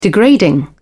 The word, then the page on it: degrading: British English